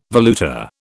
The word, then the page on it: valuta: British English